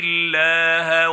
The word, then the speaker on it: الله: نجد أن فضيلة الشيخ محمود خليل الحصري قد رقق عند نطقه الحرف الموجود في لفظ الجلالة حرف اللام الله، فقرأها ومن يطع الله بترقيق هذا الحرف لمجيئه بعد العين المكسورة الموجودة في كلمة يطع.